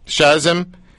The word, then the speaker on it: Shazim: Shazim Khan.